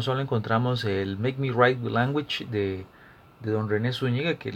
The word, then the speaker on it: Mek wi Rayt wi Langwich: El libro es un alfabeto ilustrado del criollo limonense y, si no me equivoco, se pronuncia Make Me write with Language del Limón Criol Alphabet, publicado por la EUNA, por la Universidad Nacional.